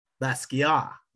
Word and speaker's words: Basquiat: If you've seen it, you've at least got to pronounce it correctly, right? – What's Basquiat. – That? Basquiat. – Is yes.